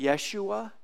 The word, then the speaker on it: Yeshua: But a third of them would say, yeah, Jesus, and Hebrew word for Jesus is Joshua. Sometimes they pronounce it Yeshua, but Jesus is the Messiah.